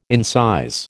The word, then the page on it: incise: American English